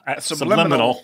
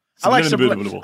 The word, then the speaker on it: subliminal: Subliminal. You added subliminal. Extra syllable there. – That is subliminal. – Yes. – Subliminal. – Subliminable. – I like subliminal better.